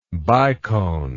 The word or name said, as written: bicone